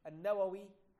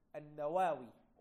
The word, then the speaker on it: An-Nawawi: He was Yahya ibn Sharaf An-Nawawi, and some people pronounce it as An-Nawawi. All this is acceptable.